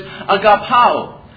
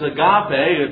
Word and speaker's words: agapao: It is the word, and I know it sounds funny to our ears because it's so foreign to us, but it's agapao. Agapao is how it is pronounced, and a more famous term or pronunciation is agape. That's a very similar word to agapao, but they're just a little bit different.